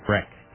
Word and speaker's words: Brecht: Breck. – Breck? – Breck. – B-R-E-C-H-T? – Yeah.